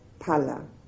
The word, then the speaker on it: phala: The actual benefit arises in the next two moments, which are called pala, P-H-A-L-A, which is fruit. So this is always called in Pali magga pala. The PH is just pronounced P.